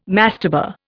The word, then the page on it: mastaba: Indian